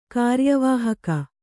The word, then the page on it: ಕಾರ್ಯವಾಹಕ: kāryavāhaka